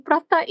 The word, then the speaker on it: prata: So actually, P-R-A-T-A is pronounced as prata, which means to speak or to talk. – Oh, interesting. – I was like, oh, it's not, you don't say prata, you say prata.